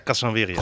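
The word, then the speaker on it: Kasanwirjo: Casanvirio, spreek je het dan goed uit? – Ja. Nana. Nasa Casanvirio.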